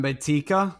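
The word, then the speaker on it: Mbaeteka: It'll include the 11 draft picks, the 14 undrafted free agents, Roy, oh God, Mbakayda. I'm so sorry, pronounce please. – Mbeteka. – Mbeteka, maybe that's the guy that OCU Manira helped recruit.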